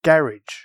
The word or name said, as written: garage